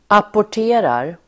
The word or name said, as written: apporterar